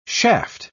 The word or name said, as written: shaft